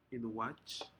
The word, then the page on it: inwaj: Aneityum